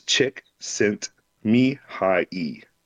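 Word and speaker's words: Csikszentmihalyi: So Symmetry Fang, the flavor text on his helmet is, there are six factors in true, and I'm gonna butcher this, but this is how you pronounce his name because I just looked it up. It's Chick Sent Me High E.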